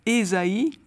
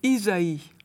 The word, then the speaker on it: Ésaïe: Euh, les catholiques, par exemple, seront peut-être un peu surpris de voir tout le temps le nom du grand prophète Ésaïe au lieu de Isaïe. On a opté pour la prononciation protestante. Alors c'est peu de choses, mais ça peut gêner épidémiquement. Et il y a une raison pour cela, c'est que le, la version protestante Ésaïe remonte au grec, alors que l'utilisation, enfin l'usage catholique de dire Isaïe vient directement de la Vulgate, donc le texte latin, où l'on disait Isaïas.